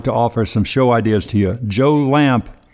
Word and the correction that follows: Lample: I'm sorry, Joe Lample is how it's pronounced and spelled, has a great podcast on organic gardening and natural methods of pest control, oh.